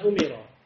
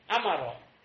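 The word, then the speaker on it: Amaro (أمر): Ma amarobihi, bukan umiro. Amaro.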